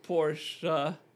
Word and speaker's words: Porsche: If it's not a Porsche, it's garbage.